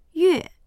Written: yue4

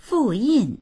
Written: fu4yin4

